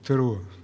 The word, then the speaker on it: Теруа: Я постараюсь правильно ставить ударение, правильно, Теруа.